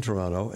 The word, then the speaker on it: Toronto: I was in Toronto, and you know I know about Toronto because I don't pronounce the last T.